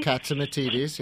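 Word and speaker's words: Catsimatidis: Someone mentioned, and I think it was John, I can't pronounce his name well, the Republican, Katsimatidis. – Katsimatidis, yes.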